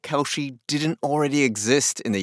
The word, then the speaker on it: Kalshi: Her name is Luana Lopez Lara, and the exchange is called Kalshi.